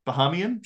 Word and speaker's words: Bahamian: Shout out to DeAndre Ayton, another Bahaman. Is that how you say it? Bahamian? – Sure.